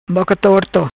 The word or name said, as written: Boca Torto